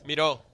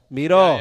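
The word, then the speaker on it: Miró: Yeah, it's well, it's with an accent in in Miro. The O. Yeah. – ¿Cómo se dice pues? – Miro. Miro. Yeah, yeah, Miro. Like, like the, the, painter.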